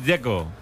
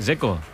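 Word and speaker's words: Džeko: Porque de Z, ¿cómo se pronuncia? – Zeko. Bruno Zeko. Dice que es Zeko. – Como tirando a J, Zeko o algo así.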